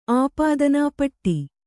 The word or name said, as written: ಆಪಾದನಾ ಪಟ್ಟಿ